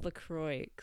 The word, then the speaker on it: LaCroix: Apparently it's pronounced LaCroix. LaCroix. I don't even know. It's not French, apparently. No, it's, no, it's from like southern US.